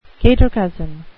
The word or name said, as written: cater-cousin